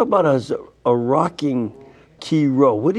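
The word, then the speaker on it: Chi Rho: So it's the, you say key row for sure, man, you pronounce it right.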